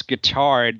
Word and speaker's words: Guittard: You had Lawrence Guittard.